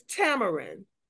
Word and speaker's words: Tamron: My name is Tamron. – Tamron, okay. – It rhymes with Cameron. That's my twin brother.